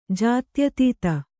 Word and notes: ಜಾತ್ಯತೀತ: jātyatīta

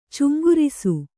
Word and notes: ಚುಂಗುರಿಸು: Kannada

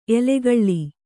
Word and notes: ಎಲುಗುಳಿ: Kannada

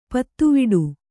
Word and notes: ಪುದಿವೆಟು: Kannada

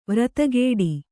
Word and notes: ವ್ರತಗೆಡು: vratageḍu

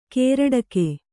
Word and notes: ಕೇರಡಕೆ: kannada

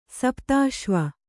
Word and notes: ಸಪ್ತಶೈವ: sapta Śaiva

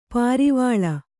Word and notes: ಪರಿವೇಲ: pari vēla

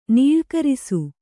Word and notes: ನೀಳ್ಕರಿಸು: nīḷkarisu

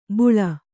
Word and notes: ಬುಳ: buḷa

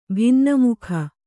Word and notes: ಭಿನ್ನಮುಖ: Kannada

